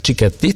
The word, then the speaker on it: Cicchetti: I Pietro Cicchetti, jeśli dobrze wymawiam jego nazwisko. Cicchetti, tak? – Tak.